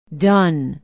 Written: Dunn

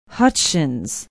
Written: hutchins

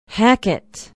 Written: hackett

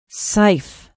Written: Seif

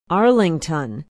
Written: arlington

